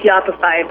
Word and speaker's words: Yoppify: She's the founder and CMO of a company called Yoppify. Am I saying that right? – You got it.